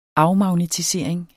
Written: afmagnetisering

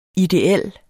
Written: ideel

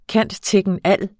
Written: cand.techn.al.

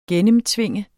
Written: gennemtvinge

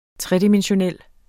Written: tredimensional

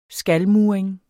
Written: skalmuring

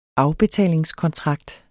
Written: afbetalingskontrakt

